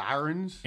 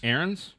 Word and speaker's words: Ahrens: A-H-R-E-N-S. Ahrens. How do you say that? Ahrens? – Ahrens.